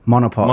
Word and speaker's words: Monopole: So you feel like you were working for Metapol, was it? – Monopol. – Monopol. I would pronounce that wrong all the time.